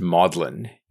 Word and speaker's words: Magdalen: So the Mary Magdalene, the word is pronounced maudlin in Oxford, mostly because of its Latinization.